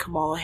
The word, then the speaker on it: Kamala: He even leapt to the defense of his old frenemy, Vice President Kamala Harris, when DeSantis mispronounced her first name. Deliberately, it seems. "It's Kamala," Newsom sounded out, "Madam Vice President, to you."